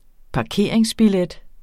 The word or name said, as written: parkeringsbillet